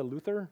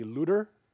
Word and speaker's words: Luther: So Martin is born in 1483 to Hans and Margareta Luther. The German pronunciation would be Luther, L-U-D-E-R, but he took the Latin version, which is Luther.